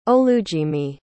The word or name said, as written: Olujimi